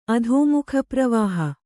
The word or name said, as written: ಅಧೋಮುಖಪ್ರವಾಹ